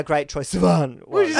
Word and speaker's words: Sivan: How do you say his name? – Sivan. – Sivan.